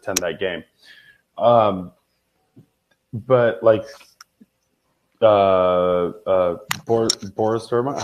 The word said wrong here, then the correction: Borussia Dortmund: how do you say it? BBB in uh, Germany. – Borussia Dortmund.